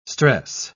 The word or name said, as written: stress